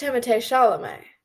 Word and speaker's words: Timothée Chalamet: It's actually pronounced Timothée Chalamet. – What? – That's actually how his name is pronounced, Hazel.